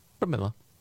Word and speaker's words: Pramila: Pramila.